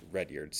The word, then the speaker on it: Rudyard: I'm sorry, actually, after we did both tours, we took a break, and then for lunch, we went down to, well, it's spelled Rudyard, but we were told it's pronounced Redyard.